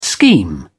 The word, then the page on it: scheme: British English